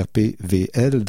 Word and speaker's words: RPWL: Le samedi, on va avoir RPWL ou RPVL, parce que j'aime mieux dire les deux, parce que le groupe a été longtemps connu ici comme RPWL, et puis là, ben, c'est pas la prononciation exacte, c'est RPVL.